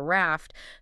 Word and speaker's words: RAFT: And we pronounce it simply as the spoken word raft, just, like the boat.